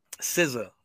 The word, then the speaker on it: SZA: But Alex is right, because I'll listen to fucking to is it SZA? Am I saying that right? – SZA, my SZA.